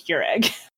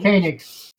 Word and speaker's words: Koenig: I keep wanting to call him Agent Keurig. Like the coffee machine. – Koenig. Yeah. Like Walter Koenig, the Star Trek actor.